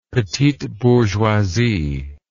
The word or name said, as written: petite bourgeoisie